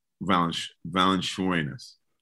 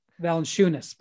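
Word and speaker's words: Valančiūnas: You know, well, one, I like, and I always butcher his name, Valanciunas. – Valanciunas, but that's okay. Jonas. – See, Valanciunas. I always butcher. At least I was honest about it, right? – You are.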